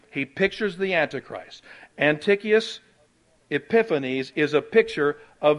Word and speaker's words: Antiochus: And all you got to do is just write Antioch, A-N-T-I-O-C-H, U-S, although it's pronounced Antiochus, Epiphanes, E-P-I-P-H-N-E-S.